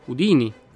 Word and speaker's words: Houdini: Cominciamo intanto col dire che il suo nome si pronunciava Udini, con l'accento sulla prima I e non Udini.